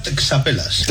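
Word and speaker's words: txapelas: Sí, sí, se pronuncia texapelas. – Es que lo habían puesto fonéticamente, pero sí, escrito es texapelas, o sea, que son las boinas.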